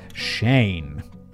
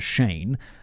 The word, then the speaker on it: Cheyne: At this second church, 12-year-old Israel Keys met 17-year-old Chevy Kehoe and his younger brother, Shane. – I mean, that is... David, again, provided me a pronunciation guide for Shane, because it's not spelt the traditional way. It's spelt C-H-E-Y-N-E, which is a bit weird, but okay.